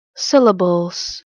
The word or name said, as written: Syllables